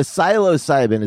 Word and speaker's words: psilocybin: So the psilocybin, is that how you pronounce it? – I think it's psilocybin.